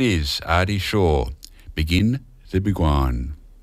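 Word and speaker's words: Beguine: We're going to move on to Artie Shaw's version of Begin the Begin, Begwine. Hard to pronounce that one, Begin the Begwine.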